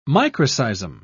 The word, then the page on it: microseism: British English